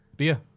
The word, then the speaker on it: Bea: Bea. – B. You never met her. – I have. – Have you just done that phonetically there? – B-E-A. – That's B.